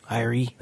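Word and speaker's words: Airi: There's also another girl that he works with named Ari. Is that how you say it? Or Ari? – Iri, that's it.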